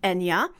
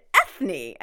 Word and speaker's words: Eithne: There's also singer Enya, whose name is originally spelled the Irish way E I T H N E. Hmm. Which I thought was interesting, still pronounced Enya. And I actually know a woman with that name here, she lives here, who pronounces it Ethne. I guess she got sick of everyone mispronouncing her name.